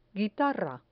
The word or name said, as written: guitarra